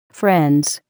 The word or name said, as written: friends